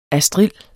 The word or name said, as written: astrild